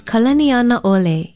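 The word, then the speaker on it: Kalaniana Ole (Kalanianaʻole): Another beautiful Hawaiian name that is so often mispronounced is Kalaniana Ole. It is spelled K-A-L-A-N-I-A-N-A Okina O-L-E, and it means the chief without measure.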